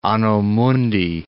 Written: anno mundi

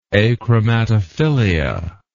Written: achromatophilia

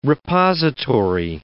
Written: repository

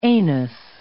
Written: anus

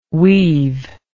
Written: we've

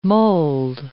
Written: mold

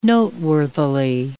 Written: noteworthily

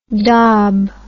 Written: dob

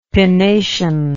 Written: pinnation

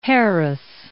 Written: Harris

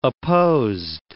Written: opposed

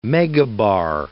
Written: megabar

